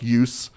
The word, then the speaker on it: jus: So jus, J-U-S, or sometimes spelled I-U-S, pronounced use, justice, rightness.